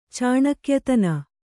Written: ಚಾಣಕ್ಯತನ

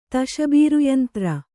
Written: ತಶಬೀರುಯಂತ್ರ

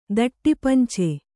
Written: ದಟ್ಟಿಪಂಚೆ

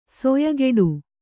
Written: ಸೊಯಗೆಡು

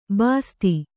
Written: ಬಸ್ತಿ

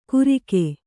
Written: ಗುರಿಕೆ

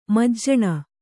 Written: ಮಾಜನ

